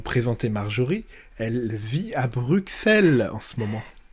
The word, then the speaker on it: Bruxelles: Pas du X, en fait c'est deux S euh, quand on l'écrit en néerlandais ou en flamand. Euh, et, voilà, il le prononce comme ça, Bruxelles.